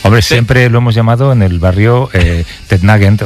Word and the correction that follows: Nugent: o Tednagent. – Claro, ¿verdad? Yo era de Tednagent. – Pero la pronunciación correcta es Nugent. – Te fuiste a Washington y descubriste que era Nugent. ¿Qué tal Ted Nugent?